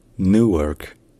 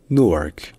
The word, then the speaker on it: Newark: C'est pour ça qu'en anglais britannique, on dit Newark. Newark. Newark, voilà. Mais en anglais américain, il n'y a pas de U. C'est juste OU. Donc, Newark. Ou bien, Newark.